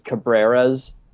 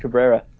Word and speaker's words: Cabrera: Like, they both work, but, so Melky and Miguel, the difference is that Melky is K-U-H, ka, and Miguel is K-A-H, ka, and then Miguel has a ra, R-A-H at the end, and Melky has an U-H. and of course, they're both Cabrera.